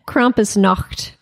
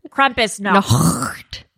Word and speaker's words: Krampusnacht: Yeah, you're German, aren't you? How do you say it? – Krampusnacht. – Krampusnacht. – Nacht.